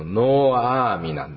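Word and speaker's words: ノ ーア ー ミ (נעמי): さんではありませんかっていうふうに声をかけたその答えに対してね、ナオミなんか呼ばない、ナオミなどと呼ばないで、むしろマラ、苦いと呼んでくださいって。全能者が私をひどい目に遭わせたんだ。この気持ちも私たちよくわかるのではないでしょうか。自分の名前がナオミ、これ原文で正しく発音しますと、ノーアーミなんですね。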